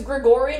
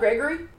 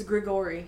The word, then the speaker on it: Grigori: So it's Grigori, not Gregory? – Well, he's Russian. – It's G-R-I-G-O-R-Y. Or O-R-I. So, Grigori. – Grigori, yeah. I wasn't asking that, like, out of, saying you said it wrong. It was a genuine question. – Oh, no, it is Grigori.